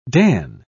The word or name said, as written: dan